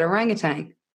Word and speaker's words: orangutan: Yeah, and then what's confusing is that you would think that there's a G, however there's not at the end. Because like, it sounds like it, orangutan, isn't it? – All those shifty silent letters surprise us.